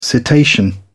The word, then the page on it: cetacean: British English